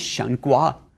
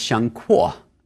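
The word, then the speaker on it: Shen Kuo: His name is Shengguo, or it's Shengkuo. This is an romanization issue. There's the same Chinese character, can be pronounced two ways.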